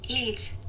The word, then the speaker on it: Yeates: Yeats.